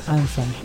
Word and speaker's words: Unsun: Ahí con el buscador Ansan. – Ansan. Se escribe Unsun.